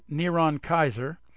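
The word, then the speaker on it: Neron Kaiser (נרון קסר): Here, using English characters, the Hebrew form of Caesar Nero is Nirwan Osra, pronounced Neron Kaiser.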